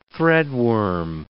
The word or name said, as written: threadworm